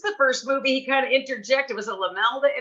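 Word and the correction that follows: Laemmle: Is that how you say it? Lemley? – Yeah, Carl Lemley. He was the. – My mother always says Lamel. I don't know why.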